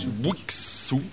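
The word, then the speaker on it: Bouyxou: Un le duo, Bouixou. – Comme ça, ça se prononce, comme ça s'écrit.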